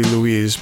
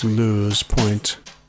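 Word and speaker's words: Louse Point: How would I pronounce that? It looks like it wants to be Louise, but it's Lou's Point.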